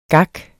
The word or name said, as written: gak